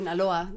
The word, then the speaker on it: Sinaloa: Sinaloa, that's correct.